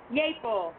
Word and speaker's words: Yeaple: Next up, we have Helen and Pete Yeaple. I think I'm saying that right. I hope. – Yeaple.